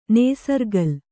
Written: ನೇಸರ್ಗಲ್

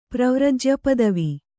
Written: ಪ್ರವ್ರಜ್ಯಪದವಿ

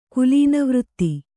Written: ಕುಲೀನವೃತ್ತಿ

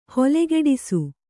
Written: ಹೊಲೆಗೆಡಿಸು

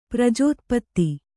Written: ಪ್ರಜೋತ್ಪತ್ತಿ

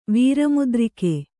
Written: ವೀರಮುದ್ರಿಕೆ

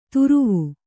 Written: ತುರುವು

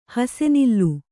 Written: ಹಾಸುನೂಲು

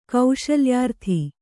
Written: ಕೌಶಲ್ಯಾರ್ಥಿ

